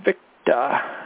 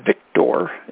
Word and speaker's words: Victor: Tango, Uniform, Victa. It's not Victor, it's Victa.